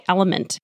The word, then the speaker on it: LMNT: Today's podcast is brought to you by Element. That's spelled L-M-N-T.